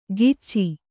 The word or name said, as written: ಗಿಚ್ಚಿ